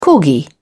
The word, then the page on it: corgi: British English